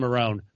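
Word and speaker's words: Marrone: By the way, I would like to point out and applaud DeFilippo and or Doug Marone. Doug Mariota. – what is going on with the pronunciation? – I know. – Doug Mariota. – I'm in my head now. I've done it twice. It's my French pronunciation of Doug's name.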